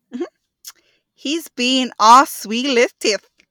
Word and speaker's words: Zwielichtig: This is the hardest word because the way you spell it is not the way you think you would say it. Oh. It's Swi-leth-teth. Whoever has the lisp, I am so sorry. I can't say this word. But this is a way. Let me play it for you. – Zwielichtig. – Oh, wow. – Zwielichtig. – But the word is spelled Z-W-I-E-L-I-C. H-T-I-G. I know.